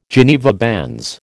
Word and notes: Geneva bands: American English

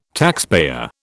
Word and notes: taxpayer: British English

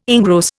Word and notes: engross: Indian

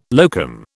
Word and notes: locum: British English

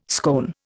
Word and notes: scone: Indian

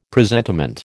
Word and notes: presentiment: American English

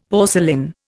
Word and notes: porcelain: Indian

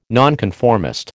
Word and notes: nonconformist: American English

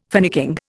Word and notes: finicking: Indian

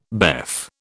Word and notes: BEF: British English

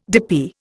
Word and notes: dippy: Indian

